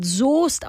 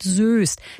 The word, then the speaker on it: Soest: Der Ort Soest in NRW wird Soest ausgesprochen, nicht Soest.